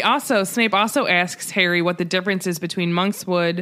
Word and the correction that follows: monkshood: is that how you pronounce it? – Monkshood and Wolfsbane.